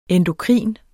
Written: endokrin

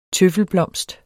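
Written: TØFFELBLOMST

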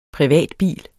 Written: privatbil

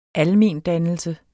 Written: almendannelse

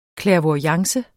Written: clairvoyance

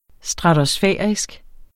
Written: stratosfærisk

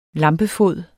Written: lampefod

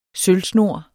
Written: sølvsnor

